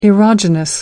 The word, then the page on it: erogenous: American English